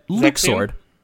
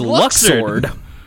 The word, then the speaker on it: Luxord: Right, no, Luke Sword. Because that's how it's pronounced now.